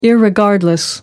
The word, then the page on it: irregardless: American English